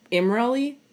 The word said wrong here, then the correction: Imrahil: No, Imrail, Imrail.